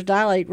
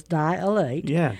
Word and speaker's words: dilate: Many people pronounce three full syllables dilate, leading to the adding an extra A while spelling it, and dilate ain't a word.